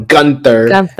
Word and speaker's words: Gunther: Gunther.